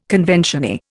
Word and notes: conventioneer: Indian

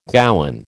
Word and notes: gowan: American English

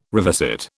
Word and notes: revisit: British English

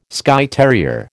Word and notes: Skye terrier: American English

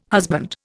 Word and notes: husband: Indian

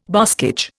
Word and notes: boscage: Indian

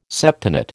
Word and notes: septenate: American English

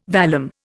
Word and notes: vallum: Indian

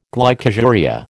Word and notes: glycosuria: American English

